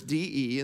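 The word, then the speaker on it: de (δέ): It's two letters, D-E, and then the Greek is pronounced de.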